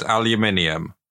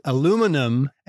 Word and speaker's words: aluminium: You want me to say garage and aluminium? I'm happy to do that. – She might be wondering whether you pronounce it aluminum and garage.